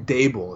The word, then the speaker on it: Daboll: So, Brian, is it Dabble? Drabble? – Brian Daboll is how you say it.